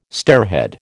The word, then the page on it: stairhead: American English